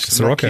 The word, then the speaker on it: Sirocco: Sirocco.